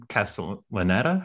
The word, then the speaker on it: Castellaneta: Homer and Simpson, they, uh, Dan Castellaneta.